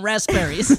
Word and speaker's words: raspberries: I'm not done eating raspberries. – You guys are really like putting an emphasis on that P in raspberries.